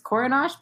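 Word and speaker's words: Kořenář: And it's Korenash, but it's spelled K-O-N. Or K-O-R-E-N-A-R. It ends in an R, and it's pronounced Korenash.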